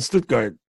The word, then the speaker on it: Stuttgart: When I was stationed in Stuttgart, I don't know if I say that correctly. – Yeah, but you do.